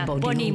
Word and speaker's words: Boni: Ja, Boni moet je het uitspreken. – Boni. Bonnie. Goed, Bonnie.